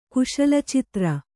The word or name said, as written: ಕುಶಲಚಿತ್ರ